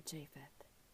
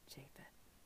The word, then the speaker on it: Japheth: After Noah was 500 years old, he had three sons, Shem, Ham, and Japheth, or Japheth, also known as the pronunciation Japheth.